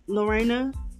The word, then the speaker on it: Lorrina: It is Lorena Freeman, L-O-R-R-I-N-A, last name, spell it just like it's said, F-R-E-E-M-A-N.